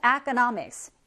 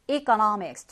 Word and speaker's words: economics: 자, 얘는요, 발음을 여러분들이 여기에 E를 academic, E라고 발음하셔도 되겠고, economic이라고 해서 E 사운드를 내셔도 무방합니다. 역시 과목명, 학문명이기 때문에 S로 됐다 하더라도 복수 취급하지 않아요. 자, economics, economics 둘다 가능하고요.